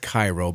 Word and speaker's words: Cairo: It's spelled Cairo, but it's pronounced Cairo. They do that in Southern Illinois.